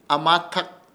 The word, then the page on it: AMATAK: Ishir; Chamacoco